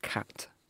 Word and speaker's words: khat: We had a nice chat about cat. – Not a cat. – Cat spelled K-H-A-T or Q-A-T, depending on how you want to spell it or pronounce it.